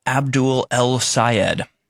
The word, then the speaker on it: Abdul El-Sayed: Michigan Senate candidate Abdul Al-Sayed. So you cannot, if you can't pronounce his name, it's easy to pronounce. Abdul El-Sayed.